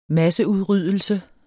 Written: masseudryddelse

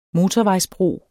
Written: motorvejsbro

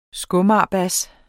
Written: skomagerbas

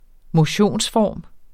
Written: motionsform

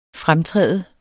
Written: fremtræde

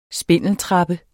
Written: spindeltrappe

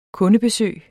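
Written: Kundebesøg

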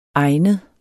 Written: egnet

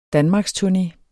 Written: danmarksturné

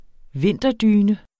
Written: vinterdyne

